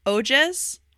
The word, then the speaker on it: Ojas: Okay, so vocab number one is Ojas, which is spelled O-J-A-S, and it's actually, it's got its foundation in Sanskrit, but it means vigor, which essentially means to refer to, like, your vitality.